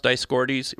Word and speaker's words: Dioscorides: In fact, I think it's, isn't it pronounced Dioscorides, recorded that black seeds were also used as a diuretic and to help promotion of the menstruation and increase milk production.